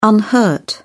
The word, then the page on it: unhurt: British English